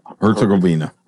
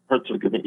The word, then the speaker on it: Herzegovina: Herzegovina. – Herzegovina, yeah.